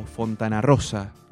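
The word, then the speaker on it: Fontanarrosa: Por ejemplo, lo tenemos al negro Fontana Rosa, en un rato nada más, o Fontana Rosa, así, porque esa es la pronunciación correcta, si no parece que hay una separación.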